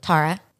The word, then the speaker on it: Tara: Tara.